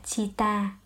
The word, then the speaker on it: citta: Phát âm là citta.